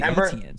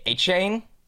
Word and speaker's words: Etienne: What was the ETN situation? – His the real pronunciation, like I believe it's the Cajun way, is A-chain. You know this with ETN? He said like people have been saying it wrong, but people still call him ETN.